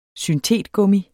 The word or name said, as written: syntetgummi